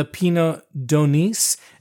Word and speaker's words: Pineau d'Aunis: So now, I don't know anything about this wine, the Pinot d'Onis, and you do pronounce the S.